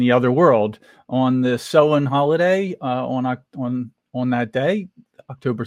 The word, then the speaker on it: Samhain: The holiday is pronounced Samhain, and it's spelled S-A-M-H-A-I-N, but it's pronounced Samhain.